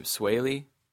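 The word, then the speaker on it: Swae: I don't know if it's a joint song with, I don't even know how you say his name, Sway? – Sounds right.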